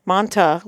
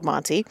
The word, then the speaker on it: Manti: Manta, we called it as a child, but I guess the correct pronunciation. I'm surrounded by my people here. They're in the know. I can make manta, which is called Monty, but it looks like a wing nut.